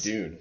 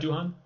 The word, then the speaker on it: Doohan: Dune. – James Dune? It's not Doohan? – Nope. – Oh, Dune. I feel silly, but James Dune.